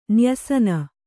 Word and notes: ನ್ಯಸನ: nyasana; Kannada

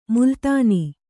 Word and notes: ಮೇಲುತನ: Kannada

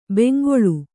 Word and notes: ಬೆಂಗೊಳು: bengoḷu; Kannada

